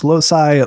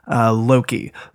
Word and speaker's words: loci: can be pronounced loci, loci, loci, or Loki. So any which way that you want, I prefer not to go with Loki because it gets confused with Thor.